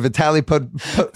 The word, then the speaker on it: Vitaly Potapenko: Trade away an unprotected pick for Vitaly Potapenko.